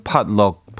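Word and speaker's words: potluck: 근데 음식 조금씩 이렇게 싸가지고 와서 다 같이 모여서 하는 파티를 그, 어, 포트럭 파티라고 하잖아요. 뭐, 미국식 발음으로는 팟럭 파티라고 하는데 뭐 일반화까지는 아니더라도 많이들 그렇게 하시나 봐요 요즘.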